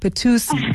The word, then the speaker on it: Patoitis: It's actually Patoitis, but that's close enough. – Ah, there we go. See, I was reading it phonetically. Patoitis, thank you so much for that, Thalia.